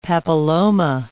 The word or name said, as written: papilloma